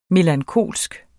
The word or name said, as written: melankolsk